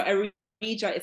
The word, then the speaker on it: Aridja: So you can connect with me on Instagram at Simply Aridja. So Aridja is spelled A-R-I-D for Delta, J-A. So Simply Aridja.